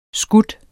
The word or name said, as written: skyder